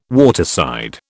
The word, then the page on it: waterside: British English